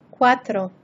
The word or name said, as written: quatro